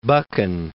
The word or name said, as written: Buchan